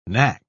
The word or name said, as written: knack